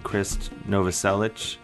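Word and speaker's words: Krist Novoselic: Giants in the Trees, a band featuring none other than Chris Novoselic, if I'm saying that right, from Nirvana. – That's right, yeah.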